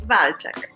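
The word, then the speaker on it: Walczak: E como é que pronuncia seu sobrenome? Só pro pessoal ficar sabendo. – Valchak.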